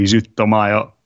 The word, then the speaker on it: Zutomayo: I I pronounced the Japanese band's name wrong as well. It's technically Zutomayo, so mm.